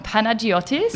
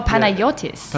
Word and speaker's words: Panagiotis: Is that how you say it? – Yeah, it's more with I spell it with a Y. Some people do spell it with a G, but... – Oh, Panagiotis. – Yeah.